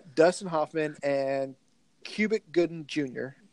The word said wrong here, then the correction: Cuba Gooding Jr.: I think it's Cuba, but it's spelled Cuba Gooding Jr. God.